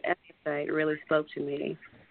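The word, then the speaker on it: Ephphatha: I know it looks really complicated to pronounce whenever you see it. But it's Ephatha, and Ephatha, Jesus actually said that in the Bible, and it means to be opened or to open yourself.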